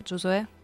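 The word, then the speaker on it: Giosuè: Да, ударение на последний слог Джозуэ Кардуччи.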